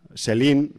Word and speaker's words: Selene: Y tenemos pues a la protagonista de la saga, que es Kate Beckinsale, que hace el personaje de Celine, Celine, o sea, se dice como Celine Dion, pero se escribe Selene, ¿no?